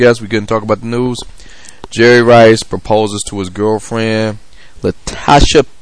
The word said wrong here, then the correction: Latisha: Latisha. Latisha with an I.